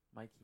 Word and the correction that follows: Miku: No, it's Miku.